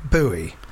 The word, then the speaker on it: Bowie: Anyone who pronounces it Bowie to rhyme with Howie or Towie is wrong.